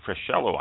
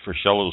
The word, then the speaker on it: Freschello: Freschello, I think it's pronounced. F-R-E-S-C-H-E-L-L-O.